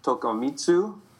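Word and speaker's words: Tokumitsu: Mia Tokomitsu. I think I said that right. – Yeah.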